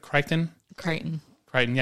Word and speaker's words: Crichton: Airframe by Michael Crichton. Did I say that right? – Crichton.